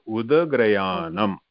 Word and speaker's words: udagrayanam: So, udagrayanam. – Okay, that is the right pronunciation.